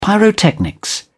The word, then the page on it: pyrotechnics: British English